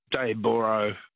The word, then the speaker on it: Dayboro: Debra. It's spelled O-R-O through me. – It is. – Yeah. It should be Debra, B-R-A-H. – Yeah. Everyone south of the border calls it Dayboro, but, yeah, fair enough.